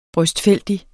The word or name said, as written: brøstfældig